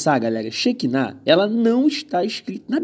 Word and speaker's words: Shekinah: A primeira é Shekinah, com acento agudo no último A.